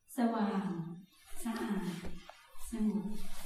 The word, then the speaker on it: Sawang Sa'a Sangop: Not quite. Sawang. Sawang means radiant. Sa'a means pure. Sangop means peaceful. Purity, radiance. How would you say it? – Sawang Sa'a Sangop. – Thank you.